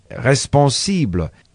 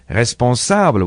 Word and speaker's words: responsable: When I wanted to say the word responsible I said it with an I as in English, instead of responsable with an A as in French.